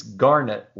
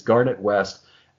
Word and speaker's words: Garnett: I think we'll assume it's Ferguson who's Sack Daddy, and it's only appropriate that he mispronounces or miswrites the name because it's Garnett, like the stone.